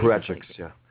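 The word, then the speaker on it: apparatchik: They have no feedback loop that allows them to make their product better in exactly the same way that Soviet Parachiks, is that how you say that? Pronounce that word? Had to their factories. – Parachiks, yeah.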